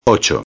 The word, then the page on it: 8: espanhol